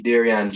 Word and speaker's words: Darrion: So on Instagram, my Instagram is I am Darion G. So my name is spelled D-A-R-R-I-O-N.